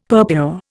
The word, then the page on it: purpure: Indian